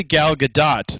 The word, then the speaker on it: Gadot: Although I actually found out fairly recently, it's actually Gal Gadot. The T is pronounced.